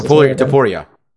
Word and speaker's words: Topuria: However, in the clips we saw from the Jai Herbert fight, I mean, you saw that Tarupa, how's it pronounced? – Topuria. – Topuria can be hit, but that man, he has a right fist that seems like it was touched by God.